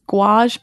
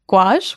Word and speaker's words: gouache: Yeah, so this is one that I've recently sort of rediscovered, and it is gouache paint. It is gouache, which is spelled gouache, mm-hmm.